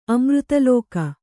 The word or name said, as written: ಅಮೃತಲೋಕ